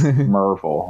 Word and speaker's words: Maryville: Yeah, their old people would have hats that Murvul, just Merville. Like, that's how you say it.